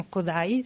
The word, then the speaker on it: Kodály: Su labor musical fue muy, muy importante a nivel mundial porque él desarrolló el método Kodály, que yo creo que aquí se conoce como método Kodály porque se escribe de esa manera. Y es un método de enseñanza musical a los niños y uh-huh. es muy, muy común, se extendió por todo el mundo y aquí creo que también hay algún instituto Kodály o Kodály, sería la forma de pronunciarlo.